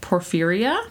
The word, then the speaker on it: porphyria: So there is a rare blood disorder called porphyria. Did I say it right? – I believe so. – Cool. – I think the few times I've ever heard of it, that's how I've heard it, I believe.